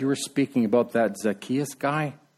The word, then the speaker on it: Zacchaeus: There's all kinds of people out there that are like Zacchaeus, notorious sinners.